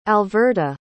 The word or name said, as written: alverta